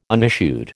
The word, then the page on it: unissued: American English